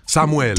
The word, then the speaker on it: Samuel: Samuel. – Damn, I sound so white. Samuel. Do I have to say it like that? – Of course, it's in Spanish. – Samuel.